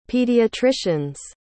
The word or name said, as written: pediatrician's